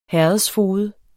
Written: herredsfoged

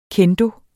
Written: kendo

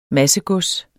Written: massegods

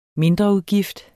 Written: mindreudgift

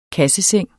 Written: kasseseng

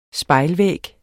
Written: spejlvæg

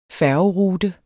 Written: færgerute

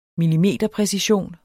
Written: millimeterpræcision

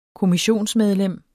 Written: kommissionsmedlem

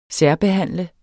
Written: særbehandle

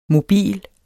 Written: mobile

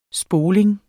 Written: spoling

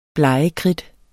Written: blegekridt

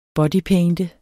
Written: bodypainter

